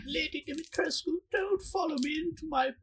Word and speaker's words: Dimitrescu: Apparently, the E is silent, first of all, so it's Lady Dimitrescu or something like that.